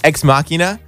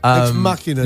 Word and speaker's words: Ex Machina: Ex Machina? – Yeah. Ex Machina.